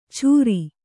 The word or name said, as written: ಚುರಿ